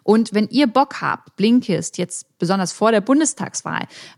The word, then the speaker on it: Blinkist: Ich wiederhole nochmal, wie Blinkist ausgesprochen wird. B-L-I-N-K-I-S-T. Blinkist, so schreibt ihr es auch.